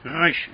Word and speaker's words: resh: 我们在学希伯来文的这个 resh 啊，我们有特别说明过。但是呢，我们普通话没有小舌音，所以你很难发音，啊，所以你就把它读成卷舌音。啊，因为它大部分时间都是卷舌音啊，你读 ra resh 啊，大家都听得懂。